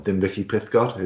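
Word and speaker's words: Dinbych-y-pysgod: I've driven through Bilthwells if you're heading down to Tenby or Dinbicky Pithgod, as you pronounce in Welsh.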